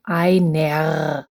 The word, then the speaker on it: einer: The word is spelled E-I-N-E-R, but we don't say einer. If there's an ER at the end of a word, we pronounce it like an A, also einer.